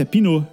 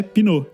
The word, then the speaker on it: pneu: Porque, porque é, um pneu em francês é pinot. É, é, a mesma palavra pneu, só que a pronúncia é pinot.